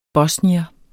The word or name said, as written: bosnier